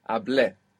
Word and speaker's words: hablé: Well, the accent is on the E. – Perfect. Hablé. Good.